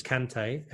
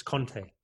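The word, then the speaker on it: Kanté: And in English, it's Kante, and in French, it's Conte.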